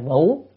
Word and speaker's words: vũ: Tức là tôn giả Sa già đà liền biến cái cơn mưa đá đó, cái chỗ này thì phải đọc là vũ, tại vì mưa đá, mà mưa đá là cứ phải đọc là vũ, cơn mưa đá đó thành một cái thích.